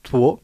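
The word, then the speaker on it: två: Le chiffre 2, c'est toi. – Toi. – Qui s'écrit TV et le A avec le rond au-dessus, qui se prononce O.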